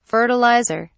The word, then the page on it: fertiliser: British English